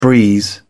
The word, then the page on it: breeze: British English